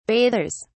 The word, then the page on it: bathers: British English